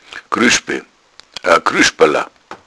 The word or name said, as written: grueschbala